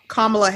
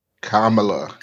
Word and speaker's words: Kamala: And they were like, you pronounce her name, it rhymes with Pamela. I'm like, oh, like my mother's name. – Kamala.